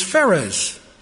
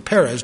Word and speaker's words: Perez: The illegitimate child that's born to Tamar is Perez, or Perez, depending on how you want to pronounce it.